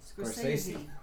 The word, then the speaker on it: Scorsese: You're just saying it wrong again. – What? Scorsese? – Yeah. – How am I supposed to say it? – Scorsese. – Scorsese? – Crazy.